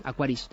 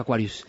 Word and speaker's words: Aquaris: Y hablemos entonces de esa cámara del BQ Aquarius X5 Plus. Ahora lo he dicho bien, ¿has visto, Chibi? Lo he dicho bien, Aquaris, ya no he dicho Aquarius.